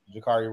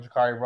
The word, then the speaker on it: Jacarri: On TikTok, I'm Jakari Rolf. On Instagram, I'm Jakari Rolf. Facebook, I'm Jakari Rolf. And you can spell that J-A-C-A, two R's, one I.